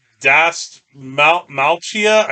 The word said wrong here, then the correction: Dastmalchian: David Dastmalchian. – Is that, did I mispronounce his name? – A little bit. – David Malchian. I didn't see the N.